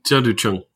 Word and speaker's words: Tianducheng: Tianducheng. – Tianducheng.